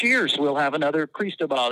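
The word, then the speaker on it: Cristóbal: The phonetic pronunciation is Cristobal, but I don't know who in a time-limited three-minute weather segment is going to go with all three. We certainly shortened it to say it as quickly as possible to just Cristobal. Mm. But yeah, phonetically, properly, it is Cristobal.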